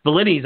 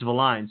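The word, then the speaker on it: Villines: I was like, Valines, I used to call him. It's Valines.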